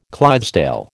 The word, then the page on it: Clydesdale: American English